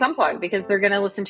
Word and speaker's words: Unpluq: We talked about this briefly before, but how do you pronounce the name of the company? – Unplug. – As if it were a G.